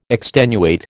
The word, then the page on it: extenuate: American English